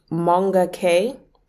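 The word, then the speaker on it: Moonga K: There's an artist called Monga K, but it's spelled M-O-O-N-G-A, and then K, full stop.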